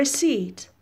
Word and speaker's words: receipt: توجه میکنید که پ is silent حرف پ تلفظ نمیشه رسید میگیرید رسید میشه رسید receipt is countable so you have a before that What is a receipt?